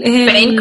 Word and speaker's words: Payne: ¿Payne como de dolor? Eh, pero se escribe P-A-Y-N-E.